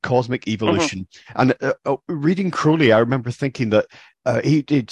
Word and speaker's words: Crowley: books, mainly Aleister Crowley, mm-hmm. Crowley, I should say. I should learn by now that's how it's actually pronounced.